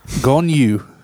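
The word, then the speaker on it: Gong Yoo: As the dad in the movie, so, Train to Busan, we have Gong Yo. – Okay. – All right. – Pronunciation? – Gong Yu.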